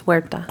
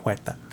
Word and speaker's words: Huerta: Tanakh Huerta. – Don't say the H, it's Huerta. – Oh, Huerta. I can't say Huerta. – Huerta. Huerta.